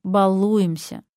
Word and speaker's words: балуемся: Потому что нормативное ударение в этом слове «балуемся». Предвижу ваш гнев. Простите, но этот литературный вариант зафиксирован орфоэпическими словарями.